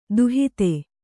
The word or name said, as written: ದೂಹತ್ತಿ